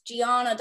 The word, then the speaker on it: Gianna: I'm so sorry if I say her name wrong, but it's Gina Darling. – Gianna Darling. Listen, I'm from the South. Sounds like Gina. Whatever. Gianna Darling.